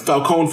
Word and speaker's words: Falcone: The Marconi family or the Marcone family, I can't pronounce Falcone family, excuse me.